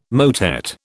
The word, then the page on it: motet: British English